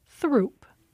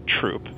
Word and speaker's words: Throop: Well, one of them runs through Bridgeport and other neighborhoods and looks like you might pronounce it Throop. – But most Chicagoans pronounce it Troop.